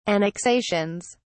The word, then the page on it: annexations: British English